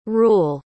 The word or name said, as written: reule